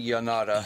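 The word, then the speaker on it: Llanada: Why was it called? Lanada Villa? – That would be Yanada.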